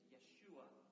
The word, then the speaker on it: Yeshua: The Hebrew way of pronouncing his name is Yeshua.